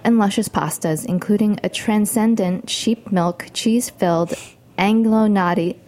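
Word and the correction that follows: agnolotti: how do you pronounce it? – Angelotti.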